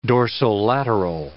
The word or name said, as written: dorsolateral